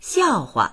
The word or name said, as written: xiao4hua